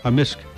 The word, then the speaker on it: amisk: Amisk. – Amisk. – Amisk. – So the K can be silent, but in generally it's...